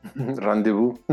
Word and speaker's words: rendezvous: Rendezvous.